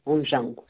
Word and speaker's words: Onjango: Eu vou, te, vou ter que te perguntar um pouco mais sobre o Onjango, é assim que se pronuncia? Exatamente, Onjango.